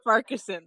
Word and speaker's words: Farquharson: Farquharson, there we go.